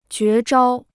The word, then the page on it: 绝招: jué zhāo